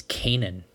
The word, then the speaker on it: Canaan: I think it's Canaan. They were pronouncing it Canaan in the game last night.